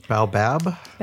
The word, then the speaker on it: Baobab: Baobab. Am I saying that right? Baobab. – Baobab.